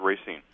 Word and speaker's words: Racine: Yeah, and I pronounce it Racine.